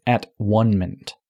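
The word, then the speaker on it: at-one-ment: achieving not atonement, but at one-ment, with hyphens and the, the word one separated, so it's pronounced at one-ment with the universe.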